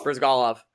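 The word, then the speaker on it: Bryzgalov: Ilya Burgachev. Burzaglov. I can't say his name. Fuck. – Brizgalov? – Brizgalov. – What'd you say it was at first? Burgachev? – Burgachev.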